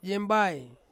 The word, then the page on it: yembai: Ansus